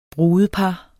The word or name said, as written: brudepar